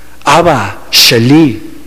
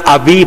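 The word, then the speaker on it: Abba Sheli: Abba, Sheli, Padre mío, es la manera correcta, Abba, Sheli. No diga Abí, porque es la forma como se escribe, pero se dice Abba, Sheli.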